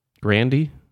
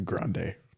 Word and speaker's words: Grande: Grandy? So I believe it's pronounced Grande. – Oh, yeah. Oh, yeah. Duh. Grande.